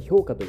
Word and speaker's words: 氷菓: 私気になります暁には最近後輩の勧めで氷菓子と書いて評価という、